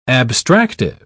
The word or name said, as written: abstractive